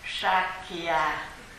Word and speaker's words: Sakya: Thì cái tên là Sakka. Sakka là tiếng Bali, còn tiếng Sanskrit Sakya. Chữ S có dấu sắc thì đọc là sờ. Chữ A dấu quyền, dấu quyền Sakya.